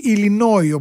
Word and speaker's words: Illinois: Και πάμε στο νούμερο 5 για να συναντήσουμε το Illinois ή Illinois όπως το λένε με την προφορά, έτσι το τελικό σίγμα δεν προφέρεται, 89.107 οι Έλληνες εκεί, φυσικά στο Illinois βρίσκεται και το Σικάγο, 0,71% των κατοίκων του ευρύτερου πληθυσμού της πολιτείας είναι Έλληνες.